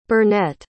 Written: bernette

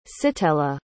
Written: sitella

